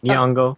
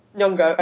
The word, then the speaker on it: Nyong'o: I just have one more bit of Black Panther news, which is and I'm totally going to get her surname wrong, Lupita Nyong'o? Is that how you say it? Lupita Nyong'o? – Nailed it. – Nyong'o. – Nyong'o, okay, cool.